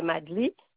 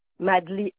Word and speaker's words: Maddly: Malaël Madli. – Ça s'écrit comment? – M-A-L-A-H-E-L. – Oui. – Madli, M-A-D-D-L-Y.